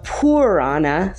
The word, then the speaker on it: Purana: Not Piranha, but Purana. Sorry about the mispronunciation.